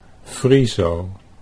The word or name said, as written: Friso